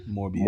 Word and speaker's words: Morbius: Did I say that right? Yeah. Morpheus. – Morbius. – Morbius.